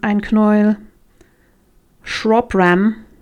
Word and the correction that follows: Shropshire: Shropshire heißt es so. Das ist dieses Shire, was manchmal ausgesprochen wird und manchmal nicht.